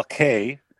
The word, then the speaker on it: K: Paul Kay. The rest is silent.